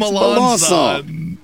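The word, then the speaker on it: Melançon: It's spelled Melancon. Okay, you don't know what it is. I'm moving on. – It's Melanson. It's Melanson.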